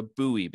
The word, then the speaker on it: Bowie: And that was when I learned that you were not pronounced the Bowie Baysox, that you're the Bowie Baysox. I mean, part of what you were doing, of course, was playing on the fact that I'm sure many people pronounce it Bowie, and you know, it's actually Bowie.